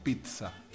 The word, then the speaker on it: pizza: Que no se dice pizza a la pizza. Es TS, así la pronunciación, pizza, ¿entendés? Entonces no es pizza, ni pizza, ni pilsa, no sé.